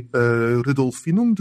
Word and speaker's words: Rudolfinum: Rudolfinum.